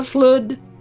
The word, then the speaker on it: flood: for has she not stood since the time of the flood? He pronounces it to rhyme with stood.